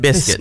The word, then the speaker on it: biscuit: Biscuit. La U no se pronuncia. – Exacto. – Without the U.